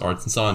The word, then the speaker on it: Arkansas: The fact that Arkansas is pronounced Arkansas and Kansas is pronounced Kansas is an abomination.